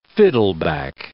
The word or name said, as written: fiddleback